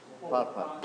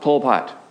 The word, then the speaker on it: Pol Pot: How do you say it? – Pol Pot. – Pol Pot, yes.